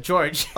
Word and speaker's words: Jorge: Jorge, band. Right? – George. – Oh, sorry. I thought it was Jorge for some reason. – That's how it's spelled, but we had No, I thought that George.